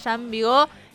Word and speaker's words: Jean Vigo: además de toda la vida y obra de Jean Vigo,